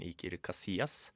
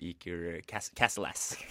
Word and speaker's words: Iker Casillas: Så da skal man jo helst ikke si Det var litt sånn som jeg gjorde med fotballspillere før, for jeg trodde alle fotballspillere skulle uttales uh, på engelsk. – Ja. – Uh, så jeg sa blant annet den spanske fotballkeeperen Iker Casillas. Og jeg er overbevist om at det heter Eaker Cas Castle-ass.